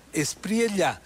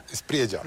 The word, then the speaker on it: Espriella: Es curioso cómo en el Ecuador pronunciamos de diferentes formas ese apellido, pero es Espriella. – Espriella. – Los quiteños diríamos Espriella, así es como hay que pronunciar.